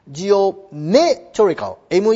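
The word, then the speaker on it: geometrical: ジオメトリカル、 ME の上がアクセント、ジオメトリカル、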